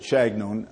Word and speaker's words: Chagnon: He is, has a French last name. He pronounces it Chagnon, uh, anglicized way, and uh, he was then uh, uh, had been teaching uh, for several years at University of California at Santa Barbara.